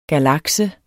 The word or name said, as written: galakse